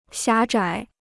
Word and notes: 狭窄: xiá zhǎi

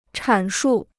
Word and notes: 阐述: chǎn shù

